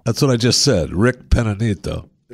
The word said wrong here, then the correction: Peña Nieto: Rick Peninita. – Peña Nieto. – So how do you say former president, former Mexican president? – Enrique Peña Nieto.